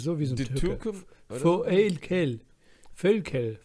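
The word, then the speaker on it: Voelkel: Völkel. – Ja, Völkel. Mensch doch. Anstatt ein Ö ist dann O-E.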